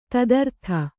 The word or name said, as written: ತದರ್ಥ